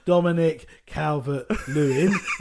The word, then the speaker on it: Dominic Calvert-Lewin: Dominic Calvert-Lewin.